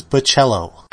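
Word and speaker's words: Bachalo: Editor's note, the correct pronunciation is Bocello.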